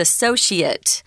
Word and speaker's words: associate: Remember, if it's the noun form, guys, it's a different sound. So associate with a long E sound or A sound there, associate, and noun is associate.